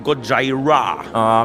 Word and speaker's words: Gojira: Man sagt einfach Gojira.